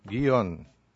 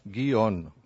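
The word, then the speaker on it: guion: Pero no hacen el diptongo guión, sino que hacen guión. Sí. O sea, hacen lo que nos llamamos un hiato. O sea, separa. La sílaba va a ser una sílaba gui y otra sílaba on. En una pronunciación. En la otra, en la anterior, es una sola sílaba, guión.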